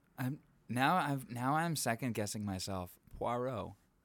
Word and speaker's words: Poirot: Everyone is calling him Perot in the movie. Everyone is pronouncing it incorrectly. – Yeah. Which that's what got me confused because like we'll get into it. – It is Poirot. – Poirot. – Like P-W-A. R-O-W.